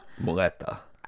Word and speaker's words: muleta: Also, the matador's cape is really called mutella. – Muleta. – I wish you would have fucking told me that before I said this. – That's not getting edited. – Fuck, yeah, I totally mispronounced that. Okay, mutella. Like Nutella? – No, muleta. You did it again.